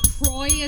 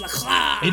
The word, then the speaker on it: LaCroix: Or LaCroix, as they say, but it's should be pronounced LaCroix.